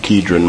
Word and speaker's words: Kidron: I've been pronouncing that Kidron my whole life, but I looked it up this time. I don't always even make an effort to pronounce it like it's supposed to be, but that's an easy one there, so we might as well call it what it is. Kidron, that's how you say it.